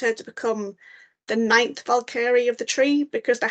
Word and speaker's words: Valkyrie: And I know from rereading Strange Maid about Signy, the young want to be Valkyrie who gets, I keep saying that word wrong, Valkyrie, not Valkyrie. I've always pronounced the I more than the E, and I know that's wrong, but it's just a habit I need to learn, dislearn.